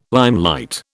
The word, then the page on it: limelight: British English